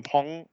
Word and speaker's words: Peng: dass die nicht Peng heißt, sondern Pong ausgesprochen wird.